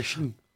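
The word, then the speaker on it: chín: Le chiffre 9, X-A-C-L-Y. – Ouais, Chine. – Ouais, et pas 9, Chine. Ça se dit Chine.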